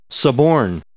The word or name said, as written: suborn